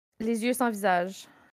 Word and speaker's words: Les yeux sans visage: Les yeux sans visage.